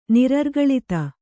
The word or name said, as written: ನಿರರ್ಗಳಿತ